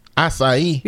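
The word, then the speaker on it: açaí: Acai? – Yeah. – I don't even know what the hell that is. – It's A-C-A-I, but it's pronounced acai.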